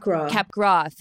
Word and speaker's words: Capgras: And I can't even begin to understand what it would be like with, is it Capgras syndrome? Is my pronounced? – Capgras. – Thank you. Capgras.